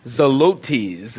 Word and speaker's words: Zelotes: Simon called the Zealot, or Simon Zelotes is how you pronounce it, actually.